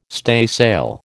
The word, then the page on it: staysail: American English